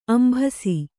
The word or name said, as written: ಅಂಭಸ